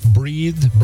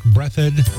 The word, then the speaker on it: Breathed: And Burke Brett, I always get confused how to say the last name, Burke Breathed, Burke Breathed, of Bloom County, a cartoonist who created Bloom County.